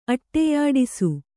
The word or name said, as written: ಅಟ್ಟೆಯಾಡಿಸು